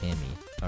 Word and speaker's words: Gemi: If you want to know how to spell it, it's B-U-L-G-O-G-I. Okay, I think. And then my Instagram is The Daily Life of Kemi. It's an ant in Korean, but it's spelled G-E-M-I. Right, Daily Life of Kemi.